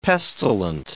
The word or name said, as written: pestilent